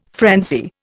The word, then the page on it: frenzy: Indian